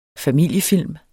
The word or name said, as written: familiefilm